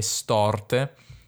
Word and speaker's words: storte: Allora, in realtà la O chiusa sarebbe solo su corse, poi bisognerebbe dire morse e storte, ma non so, lui le pronuncia così, non so se è un modo di farle rimare meglio, però questo è il suo modo di pronunciarle.